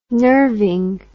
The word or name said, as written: nerving